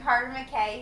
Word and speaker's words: Carter McKay: I have got my good friend Carter McKay here.